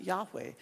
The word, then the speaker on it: Yahweh: We sometimes pronounce it Yahweh. They would choose not to say that and instead refer to God as Lord.